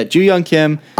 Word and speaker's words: Joohyung Kim: Um, Joohyung uh, is that what did I say that right? Yeah, Joohyung Kim.